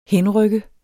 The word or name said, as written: henrykke